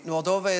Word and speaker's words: Moldova: Moldova is the most impoverished nation in Europe, the highest levels of unemployment.